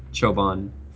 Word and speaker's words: çoban: I even in my discovery that it is based in the Turkish word çoban for shepherd, and that the Turkish letter C is pronounced ça, like as a sort of a J.